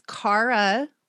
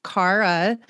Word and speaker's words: Cara: So it's Cara, like driving a car. Cara.